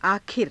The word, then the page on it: aakhira: Hindi